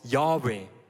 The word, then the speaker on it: Yahweh: Im Hebräischen gibt es keine Vokale, Darum, man ist sich nicht ganz sicher, wie das ausgesprochen wurde, aber es gibt einen Konsens bei den, bei den Gelehrten und bei den Rabbinen, dass dieser, diese Buchstabengruppe, diese Konsonanten wurde als Yahweh ausgesprochen.